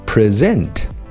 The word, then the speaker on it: present: 那么另外还有一点大家要记住， present 这个单词如果重音靠后读作 present，就成了动词提交、呈现，也就是把东西放到你面前这么一个意思啊。